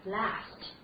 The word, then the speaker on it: last: we have this word last. 啊，这是典型的美式发音，啊，舌位非常的低，舌尖抵住下齿音，last.